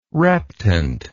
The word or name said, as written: reptant